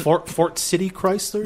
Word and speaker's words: Fort City Chrysler: Our main sponsor is Ford Motor Chrysler. Uh-huh. Say that right? And Fort City Chrysler. – There you go, Fort City Chrysler. – Yeah, okay. – My accent. It's a T.